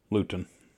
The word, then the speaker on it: Luton: They were formed in 1967 in Luton, England, or Luton, England. How would you say that? – Spell it. – L-U-T-O-N. – Luton. – Luton. Yeah. So I was almost right.